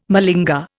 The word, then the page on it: malinger: Indian